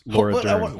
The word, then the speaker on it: Laura Dern: Lord Dern, Jurassic Park's Lord Dern is in it. Playing, what would you say? – Jurassic Park's Laura Dern.